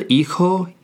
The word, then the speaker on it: hijo: El hijo y la hija el hijo y la hija. Keep in mind that H at the beginning of both words is silent.